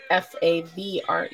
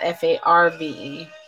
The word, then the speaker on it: Favre: You know, Farb is Favre. – Yes. – Not Farve.